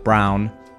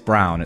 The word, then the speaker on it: Braun: Is Braun, I'll put it on screen right now, this pronounced like Braun or is it Braun? I think I'm pretty sure it is Braun, it's not Braun.